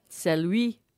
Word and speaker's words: Celui: It's spelt C-E-L-U-I. – Celui. It's French.